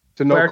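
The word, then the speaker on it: Tenoch: Is it Tanakh or Tanoke? – Tanoke Huerta, who plays Namor in this film.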